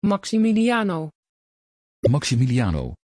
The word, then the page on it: Maximiliano: Dutch